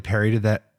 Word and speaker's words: parodied: How do you say that? Parried? – Parried. – did. – Perry did it. – I know. I'm like, is there an it was episode? Parodied. No, it was parodied.